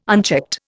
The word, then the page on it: unchecked: Indian